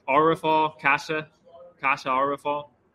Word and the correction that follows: Kaija: Is that how you pronounce it? You know, I believe it's Kaija. – Kaija, yeah.